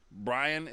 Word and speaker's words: Brian: Because if we still said it the same way you say Ryan, like Brian, then of course that's two syllables. I'm not an idiot. But nobody says it that way anymore. – People say Brian. – Brian. One syllable. Brian.